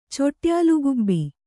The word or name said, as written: ಚೊಟ್ಯಾಲುಗುಬ್ಬಿ